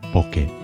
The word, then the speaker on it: bokeh: The correct pronunciation is bokeh. With Roman alphabet characters, it's spelt B-O-K-E or B-O-K-E-H with an H on the end, which joined to the E helps English-speaking people to pronounce it bokeh.